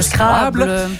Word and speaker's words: Scrabble: Et on termine l'émission aujourd'hui en parlant du bon vieux Scrabble, ou prononcé correctement en français, le Scrabble.